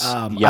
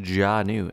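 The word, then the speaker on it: Jaanuu: No, but apparently it's pronounced Janu, and like I was sponsored by them for like a year, and I didn't even know that. – It's got two A's, bro.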